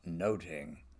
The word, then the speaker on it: nothing: but in Elizabethan pronunciation, it would have been Much Ado About Noting.